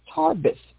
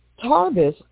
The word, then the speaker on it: Tharbis: Homegirl's name was Tarbis. It's spelled T-H-A-R-B-I-S. And Tarbis aroused the bitter jealousy of his sister Miriam.